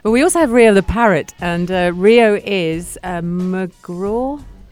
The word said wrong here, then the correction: macaw: McCaw? Well, you could say it like that, if you want, but it's called macaw.